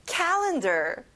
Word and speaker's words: calendar: 달력, calendar. 여러분 앞에 cat의 강세를 줘보세요. Calendar. Good job.